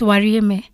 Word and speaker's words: Wariyeme: So it's an Embu name accent. And then it has a cap, so the accent. Mm. Yeah, so it's Wariyeme.